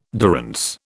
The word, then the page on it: durance: British English